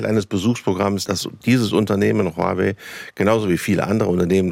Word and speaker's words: Huawei: Huawei spricht man es Huawei, aus, ja. Man hört es so oft falsch.